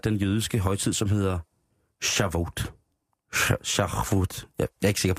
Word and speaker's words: Shavuot: Den er god, den man krasser i halsen. – Og det er lige præcis, og det er Shavuot. Nu sagde jeg det. Shavuot.